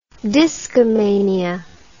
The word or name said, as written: discomaniac